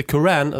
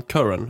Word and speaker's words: Curran: Där satt jag uttalet, va? – Ja, det gjorde de faktiskt. Bara, inte Curran, utan Curran. Har jag också lärt mig under det här träningsläget.